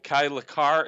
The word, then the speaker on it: Kai LaCar: But Jake Huspen and Kyle uh, Kai LaCar, is that how it's yep.